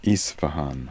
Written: Isfahan

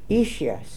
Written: ishias

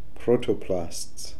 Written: protoplasts

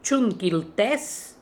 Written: Choon Kil Tez

